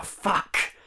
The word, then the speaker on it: Fuchs: I'm reading Joanne Fuck. Joanne Fuck. – I'm reading Joanne Fuck. It's spelled F-U-C-H-S.